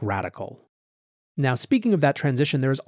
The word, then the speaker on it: Radkl: He's investing in an individual capacity in a firm called Radical, although it's spelled R-A-D-K-L.